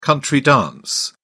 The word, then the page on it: country-dance: British English